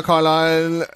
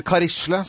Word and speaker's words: Carlisle: Linda Carlisle, eller Karisle som jeg har kalt henne en gang også.